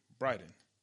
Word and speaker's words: Bryden: B-R-Y-D-E-N. Bryden.